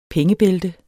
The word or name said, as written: pengebælte